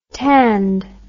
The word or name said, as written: tanned